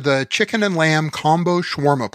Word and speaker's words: shawarma: The shawarma is there. Am I pronouncing that right? Shawarma. – There you go.